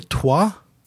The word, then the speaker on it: Twa: And I guess the third ethnic group would be the Twa. – That's right. Yeah, and good pronunciation.